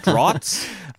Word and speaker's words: draughts: with whiskey bottles, which was or drafts. Is that how it's pronounced? The British version of the game? It's not a different game. They just call it something different. – Draughts. I do really, I did really like that it was checkers and not chess.